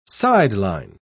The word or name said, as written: sideline